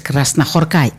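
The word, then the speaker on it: Krasznahorkai: Γιατί οι Ουγγαρέζοι, οι Ούγγροι, οι Μαγιάροι, όπως θέλετε πείτε τους, και οι Φιλανδοί δεν τονίζουν πουθενά αλλού τις λέξεις παρά μόνο στην πρώτη συλλαβή του κάθε ονόματος. Ναι, είναι, πώς τον λέγαμε εκείνο τον εξαιρετικό, ήταν ας πούμε ο Κος Κενιέμι, ήταν κάποια άλλα ονόματα, ένας εξαιρετικός οδηγός ράλι. Ναι, όλα είναι στην πρώτη συλλαβή του ονόματός τους. Εκεί τονίζονται, άρα λοιπόν Κράσνα Χορκάι.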